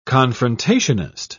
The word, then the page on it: confrontationist: British English